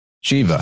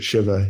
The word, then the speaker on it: Shiva: And he says, he pronounces it Shiva. He's from, you know, his family's from India, but mostly we hear it pronounced Shiva over here. But after that, I usually say Shiva, but it doesn't matter, the Shiva Lingam or the Shiva Lingam.